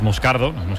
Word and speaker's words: Moscardo: Como bien ha pronunciado Unai, es Moscardo, es brasileño, sí, sí.